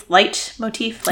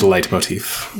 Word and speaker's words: Leitmotiv: He pronounces it Leitmotiv. I think it's Leitmotiv. – It is Leitmotiv. – Yeah, but we learn things by reading. – Yeah. – It's fine if you don't know how to pronounce something. Right. So yeah, Leitmotiv is correct.